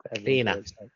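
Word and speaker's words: Cliodhna: Kleena. – What's that? – Kleena Maloney. So Kleena. Think of it, drop the DH. It's C-L-I-O-N-A and pronounce it that way. It's Kleena Maloney.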